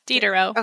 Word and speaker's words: Diderot: Diderot.